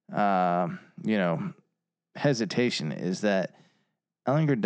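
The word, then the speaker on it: Ehlinger: Texas feels like was Sam Ellinger. Ellinger. What's the ruling on that? How do you say his last name? – Ellinger.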